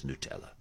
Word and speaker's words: Nutella: And they say, we don't mind if you call it Nutella, but the official pronunciation is Nutella.